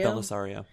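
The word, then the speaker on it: Bellisario: Is that how you pronounce it? Troyan Belisario? – Belisario, yeah.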